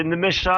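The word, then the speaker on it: Nmecha: Félix Mecha ou Nemecha, comment on prononce? – Oh, c'est tout en une seule, enfin tout d'un seul coup. C'est Nemecha.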